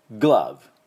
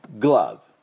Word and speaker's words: glove: でグローブで V、最後しっかり V、唇をね前歯に当てて V、で終わってください。 Glove Glove、この音です。